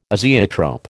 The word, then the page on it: azeotrope: American English